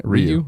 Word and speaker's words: Ryu: Ryu.